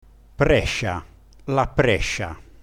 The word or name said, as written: prescia